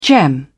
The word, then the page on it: gem: British English